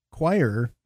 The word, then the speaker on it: quire: Q-U-I-R-E, choir.